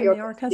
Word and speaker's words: Mayorkas: Any surprises for you in them looking at Yellen for Treasury Secretary, Haynes for the Director of National Security, Mayorkas? Is that how you say his name? – Mayorkas? Yeah.